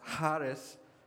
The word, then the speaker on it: charis: Grace here is the Greek word charis. It's actually how it's pronounced, charis.